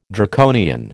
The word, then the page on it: Draconian: American English